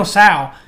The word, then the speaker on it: Tso: We're going to back it up because it's not General Cho chicken. It's not General Cho chicken. It's General Tso. – So. – General Tso chicken. – General Tso, General Cho. – General Tso chicken. It's spelled TSO.